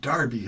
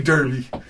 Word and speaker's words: Derby: Thank you, Davey from Crime Wolf out of Nottingham, Derby. Although I think they pronounce it Darby, huh? Like Darby Crash. – They do. – Yeah, they don't say Derby.